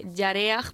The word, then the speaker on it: Yareaj: Pues mi padre es músico también, es guitarrista, y Yareaj, porque mi nombre se escribe con J al final,